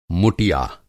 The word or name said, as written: मुटिया